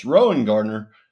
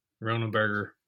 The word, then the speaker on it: Rowengartner: It's Rowengartner, of course. Ronenberger. Ronald Gardner. I'm like, it's Rowengartner. Like he's your ace pitcher.